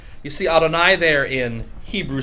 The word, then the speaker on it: Adonai: And it's pronounced Adonai. Adonai. You notice here the difference between the, in the A, one is an I and the other is an uh.